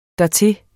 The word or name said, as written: dertil